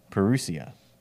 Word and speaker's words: parousia: It's the Greek word here is used is parousia. Am I saying that right? Parousia. – Yeah, I say it more like a Texan, parousia.